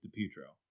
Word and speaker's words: DiPietro: Well, I wanted to say, did you know, thinking of Rick DiPietro's career, apparently we've all been saying his name wrong forever. It is supposed to be DiPietro.